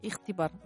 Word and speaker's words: Ихтибар: «Ихтибар». Да, это что-то между «Х» и «Г».